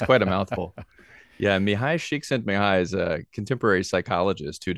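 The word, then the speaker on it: Mihaly Csikszentmihalyi: I'm going to say his name and then you correct me. Okay. Mali Csikszentmihalyi. – Yeah, I've been told it's Mihai Csikszentmihalyi.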